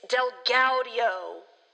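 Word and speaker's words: DelGaudio: His name is Derek Del Guardio. Guadio? – As it's spelled, it looks like Del Guadio to me. – Okay, Derek Del Guadio. Thank you. – It's Del Gaudio.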